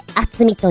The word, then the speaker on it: 熱海: この番組は、チョアヘオ .com のご協力て放送しております厚みの字は熱海と書いて厚みと読み